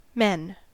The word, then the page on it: men: American English